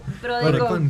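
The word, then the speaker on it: PRODECON: Entonces, justamente existe la PRODECOM. ¿Lo dije bien? – ¿PRODECOM? – PRODECOM con N.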